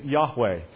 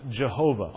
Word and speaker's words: Yahweh: His name is Yahweh. Anglicized, it is sometimes pronounced Jehovah.